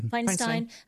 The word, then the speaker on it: Feinstein: Feinstein.